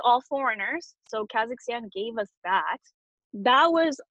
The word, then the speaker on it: Kazakhstan: So it's Kazakhstan.